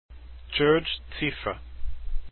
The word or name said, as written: cziffra